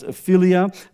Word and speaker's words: philia: Some people may pronounce it, but it's philia.